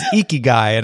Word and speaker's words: Ikigai: All right, let's get to my first one, which is Ikigai, and I'm not saying it wrong. That's how they said it on the Economist podcast where I first heard about it.